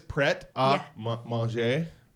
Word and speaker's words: prêt à manger: So It is prêt à manger. – Yes. Yeah. Prêt. But you can call it Pret. Yes. – Right. They just call it Pret.